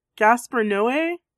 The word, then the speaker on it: Gaspar Noé: Is that how do you pronounce his name? Gaspar Noé? – Sure.